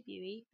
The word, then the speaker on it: Bui: If you want to hear more from me, please follow me at my Twitter, which is at Mia Lucy Buey. Buey is spelled B-U-I, where you can hear about regular updates on the podcast.